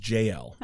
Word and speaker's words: Yael: So it's written Yael, but I think it's pronounced JL.